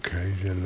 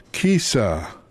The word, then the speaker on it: Kajsa: Oh, Kaja love. Kaja. Looks like it's pronounced one way, but it's not pronounced. Yes. Kisa.